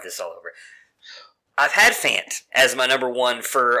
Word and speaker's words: Fant: Did I go Font and Fant? It's Font, right? – I call him Fant, but I believe it's Fant.